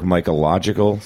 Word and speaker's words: Mycological: She's the head of the New York Mycological Society. Am I pronouncing that right? – You got it.